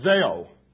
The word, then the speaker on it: Zao: Zao is the Greek word. We would spell it Z-A-O, long O, Zao in English letters.